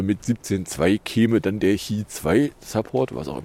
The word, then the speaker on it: Qi 2: dann kann das Qi 2. Oder wie es korrekt ausgesprochen wird. Qi 2.